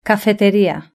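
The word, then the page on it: cafeteria: European Spanish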